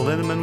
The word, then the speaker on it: Linneman: Val Linneman, which rhymes with cinnamon,